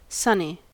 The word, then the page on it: sonny: American English